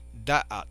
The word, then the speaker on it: Da'at: Y la tercera es Da'at, con acento en la primera A. Da'at.